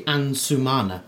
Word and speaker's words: Ansumana: I think I pronounced it wrong, and I have been pronouncing it wrong for a long time. It's actually Ansumana.